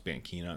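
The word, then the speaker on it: Banchina: I know it says Bankina online, it's pronounced Bankina according to the wiki.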